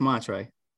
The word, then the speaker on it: Montre: How do you pronounce it? Montre? Mantra? – You pronounce it right. It's Montre.